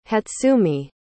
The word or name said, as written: hatsumi